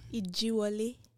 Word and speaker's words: Ijiwole: Ijiwole.